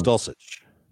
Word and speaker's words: Dulcich: Your winner was Greg Dulcich. Am I saying that right? From UCLA? – I believe it's Dulcich.